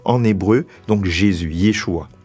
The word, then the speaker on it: Yeshua: Alors, c'est vrai qu'en français, on, le dit Jésus, mais en fait, en hébreu, c'est Yeshua. Yeshua.